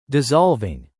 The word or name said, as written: dissolving